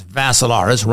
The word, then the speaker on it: Vassilaris: You can also see it on my profile page, Dimitri, with three I's, D-I-M-I-T-R-I. The last name is Vassilaris, rhymes with Assilaris, but you add a V to it.